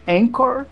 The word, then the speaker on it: Anchor: É, essa ferramenta chama-se Anchor. Aí, tá bom a minha pronúncia? – Excelente. – Olha aí, em português seria Anchor, né? A-N-C-H-O-R.